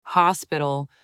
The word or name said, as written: hospital